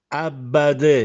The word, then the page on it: Abbadè: Castellanetano